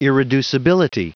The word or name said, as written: irreducibility